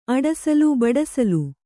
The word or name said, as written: ಅಡಸಲ